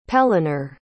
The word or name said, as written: pellinore